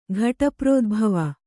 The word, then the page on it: ಘಟಪ್ರೋದ್ಭವ: kannada